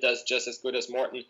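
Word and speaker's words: Maurten: How do you pronounce the brand name? – Moreton.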